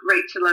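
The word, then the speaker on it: Rachael: So my handle is at Rachel Elizabeth Lift. My name is spelled a little bit unusually, so I'm an AEL, not an EL.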